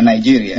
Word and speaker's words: Nigeria: Yeah, Nigeria.